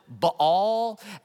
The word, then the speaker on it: Baal: I have heard a few people pronounce it Baal, and that's just weird to me. I just don't like that. So I'm going to say Baal, like Baal of hay.